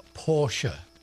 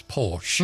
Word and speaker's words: Porsche: 1875, Ferdinand Porsche. – Ferdinand. – Porsche is the German production of what we more commonly say these days is Porsche. – Mm. – But the German pronunciation is Porsche.